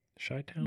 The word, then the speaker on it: Chi-Town: Is it Chi-Town or Chi-Town? – Chi-Town. – The professional pronouncer? – I hear a lot of people call it Chi-Town. – Chai? Mm-hmm. Like chai tea? – Yeah. Chai.